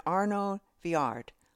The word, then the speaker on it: Arnaud Viard: I rented a movie through iTunes, and it's called Paris Can Wait with Diane Lane, Alec Baldwin, and Arnaud Viard. I hope I'm pronouncing it right. – I think you nailed it in one.